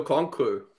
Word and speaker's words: Okonkwo: But one guy that I did want to mention, and he got another touchdown this week, was Okonkwo. Is that how you say his name? – Chigo Okonkwo.